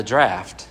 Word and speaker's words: draught: And when we talked about how in shipbuilding, in engineering, there's this thing called the draft, and it's spelled D-R-A-U-G-H-T. It doesn't sound how it's spelled, but the draft of a ship.